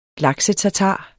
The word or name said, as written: laksetatar